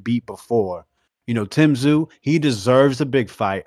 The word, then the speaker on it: Tszyu: Like you said, the three C's, the triple C's, but you don't see them parading, talking about Tszyu. Charlo mispronounces his name all the time, Kazoo.